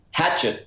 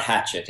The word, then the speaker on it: Hachette: Somebody did say the other day when I was being interviewed that it was Hachette Books. It is not Hachette. It is Hachette.